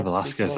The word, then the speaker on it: Velasquez: Kane Velasquez, that's how you pronounce it.